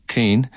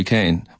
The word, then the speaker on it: Keane: My mother, God help her, her maiden name is Mary Keane, but she, in West Kerry, she's known as Mary Kane, much to her chagrin. it's just the way that they pronounce particular names.